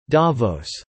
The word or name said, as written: Davos